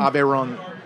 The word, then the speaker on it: Aveyron: A-V-E-Y-O-R-N, I believe. – That's a region? – Aveyron. R-O-N, making...